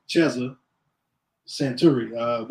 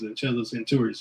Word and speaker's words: Cheza Santuri: Yeah, it's pronounced, it's the M is silent in Swahili, so Cheza Santuri, which means disc jockey in Swahili over in Africa.